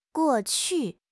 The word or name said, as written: guo4 qu4